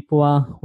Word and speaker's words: Pua: Kimi, Pua, welcome to the Gratitude Podcast.